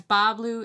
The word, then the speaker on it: Bas Bleu: And that's Ba Blue. It's spelled B-A-S-B-L-E-U.